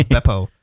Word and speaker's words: Beppo: Beepo or Grodd? – Maybe it's pronounced Beppo. B-E-P-P-O.